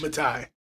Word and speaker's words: Mattai: Matai.